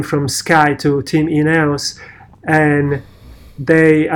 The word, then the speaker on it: Ineos: He's the Ineos, how you say it? – Ineos, I think is how you say it.